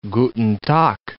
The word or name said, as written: guten Tag